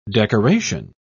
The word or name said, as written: decoration